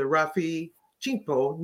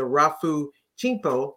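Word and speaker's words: Rafu Shimpo: Currently living in her birthplace, Pasadena, California, she was an editor of the Rafu Chimpo newspaper. I said that wrong. Let me say it again. The Rafu Chimpo newspaper, where you were a journalist.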